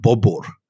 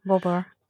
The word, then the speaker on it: Bobur: In Uzbekistan, they call him Bobur, is the Uzbek pronunciation of his name. – Bobur.